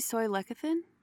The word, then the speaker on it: soy lecithin: Do you know if soy lecithin, am I saying it right? Soy lecithin? – Soy lecithin, yeah, that's a kind of a phospholipids extracted from soy, but not for the same application.